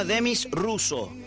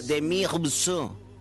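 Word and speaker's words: Demis Roussos: Una señora me dice: se llama Demi Rousseau. No. – Rusia. – Rusos, se escribe rusos con dos S, pero se pronuncia Demir Rousseau.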